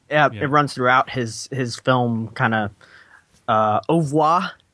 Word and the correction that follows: oeuvre: Is that, did I say that right? – I think it's oeuvre. – Oeuvre. Okay.